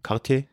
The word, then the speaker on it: Quartier: Quartier.